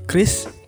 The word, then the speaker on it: QRIS: Mungkin dulu tuh Chris Dayanti gitu ya, kalau sekarang jadi Chris Dayanti gitu. Atau mungkin dia itu di endorse sama Bank Indonesia ya, untuk mengkampanyekan bahwa Qris itu dibacanya Chris gitu. Bukan Chris, bukan Qris tapi Chris gitu.